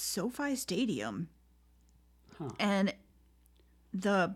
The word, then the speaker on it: SoFi: I've heard people pronounce it Sophie Stadium, but I also watched a video of the gentleman who built it. Well, not built it, but designed it. And he pronounced it SoFi, so I'm going to go with his pronunciation.